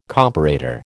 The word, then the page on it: comparator: American English